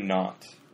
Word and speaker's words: naught: Then you could say in that instant all my dreams, all my aspirations came to naught. So 발음을 똑같이 해요. N-O-T하고 N-A-U-G-H-D 근데 의미는 달라요.